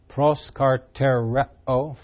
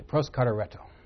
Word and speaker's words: Proskartereo: It's all one verb in Greek. Proskartereo, five syllables. We don't have a lot of pentasyllabic words in English. But Greek likes things like that. They continued steadfastly. Proskartereo.